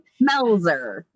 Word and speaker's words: Melzer: Melzer. – Melzer. Okay. So it is like it's... – Like it is like it's spelled.